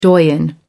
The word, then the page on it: doyen: American English